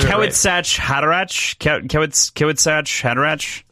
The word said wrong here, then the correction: Kwisatz Haderach: If I can see it, I can say it. Kwisatz Haderach. – Kwisatz Haderach. That's how it's pronounced there. That's how you just said it.